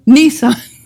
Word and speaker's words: Nisan: We're actually in a season of the Lord called Nisan. I think I pronounced it right. Nisan or Nisan? Nissan. Sorry. I didn't want to sound like I was talking about a car.